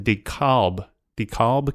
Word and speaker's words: DeKalb: This is in DeKalb County. Did I say that right? – Yeah, I think that's correct.